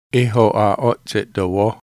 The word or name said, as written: ho'a'o'tse'tovoho